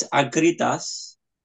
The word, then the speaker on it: Akritas: The way the Greek works is that you use the soft version of the letter, unless it's a double letter. So it's Akritas.